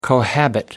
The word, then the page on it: cohabit: American English